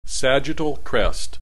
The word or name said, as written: sagittal crest